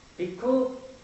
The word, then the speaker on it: bhikkhu: 但是巴利语它的发音就是 bhikkhu，它由字根 bhik 而发展来的。